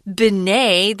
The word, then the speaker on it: Binet: It's a French name. So it's Binet. They don't like to pronounce that last syllable if it has a T at the end, especially Binet Simon.